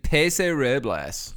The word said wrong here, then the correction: Paso Robles: Paso Robles. It's Robles.